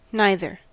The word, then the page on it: neither: American English